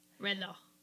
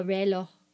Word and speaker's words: reloj: My Spanish is not awesome, but El Rejo. – Relo. – El Relo. Oh, did I spell it wrong here? – Yeah. – R-E-L-O-J. Relo.